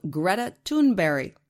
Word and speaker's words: Greta Thunberg: 17 year old Greta Thunberg, which is how they say her name in Sweden.